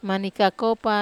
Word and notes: mani kakopa: Wamesa

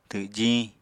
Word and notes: twjiñ: apatani

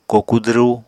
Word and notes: koku dwru: Apatani

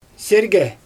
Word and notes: sirgɛ: Xyzyl

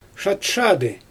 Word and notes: ʃatʃadɯ: Xyzyl

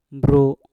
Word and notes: bru: Mpur

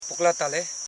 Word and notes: puklatale: pu.kla.ta.le

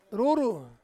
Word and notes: ruru: Ansus

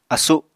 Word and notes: asu: Apatani

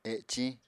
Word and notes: eciñ: Apatani

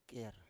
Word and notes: ker: Biak

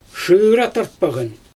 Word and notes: ʃɯra tartpaGan: Xyzyl

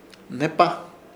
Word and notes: Hnepa: Ishir; Chamacoco

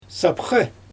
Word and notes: sapxE: Xyzyl

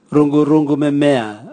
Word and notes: rugurugu memea: Raga

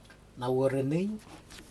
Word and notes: nawuranig: Aneityum

